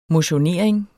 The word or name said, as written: motionering